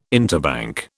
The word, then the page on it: interbank: British English